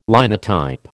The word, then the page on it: Linotype: American English